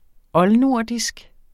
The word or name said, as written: oldnordisk